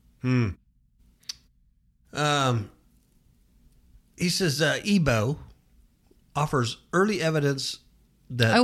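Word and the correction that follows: aibo: Oh, it's Ibo. – Ibo. – It's pronounced, yeah. – Ibo. Ibo.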